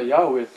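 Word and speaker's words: ヤ ウェ (יהוה): これ時々誤解されてましてね、普通私は今ヤウェって発音するんですが、あの部分は今でもユダヤ教の方々は発音しません。声で発音しませんから、ヤするか、そこに来たらアドナイという言葉を言います。そのアドナイという言葉の母音を当てはめてヤウェって発音するんですが、その名をヤウェと言ったりするなという意味ではありません。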